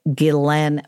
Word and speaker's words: Ghislaine: That's how you say it, Ghislaine Maxwell.